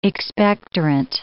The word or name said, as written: expectorant